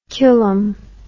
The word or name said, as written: culum